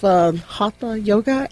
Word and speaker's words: Hatha Yoga: I had to bring you back because you're going to help us with some foundational stuff working with Hatha Yoga.